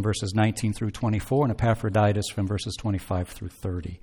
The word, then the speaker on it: Epaphroditus: If you ever come across his name in the scriptures and you wonder how in the world do you pronounce that, it is Epaphroditus.